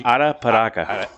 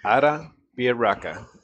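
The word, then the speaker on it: Arapiraca: Say Arapiaca. – Arapiaca. – Arapiaca. – Yeah. – Pretty good, buddy. – Break it up into three different syllables. Arapiaca.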